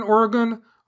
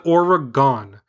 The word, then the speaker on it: Oregon: Today, in your Bigfoot podcast, you, like most non-Oregonians, said Oregon. And when we hear that, we cringe. Smiley face emoji. The correct pronunciation is Oregon, with the emphasis on the first syllable.